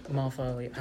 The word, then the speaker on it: Malvolio: But so, Malvolio, how do you spell it? – M-A-L. – M-A-L. – V-O-L. – V-O-L. – I-O. – I-O. – Malvolio. – Malvolio. – Like Benvolio, but Malvolio.